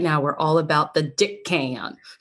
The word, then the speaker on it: Dickon: In fact, I mispronounced it in my audition for it, right? Called him Deacon because I'm like, Daikon, maybe Deacon, Dickon.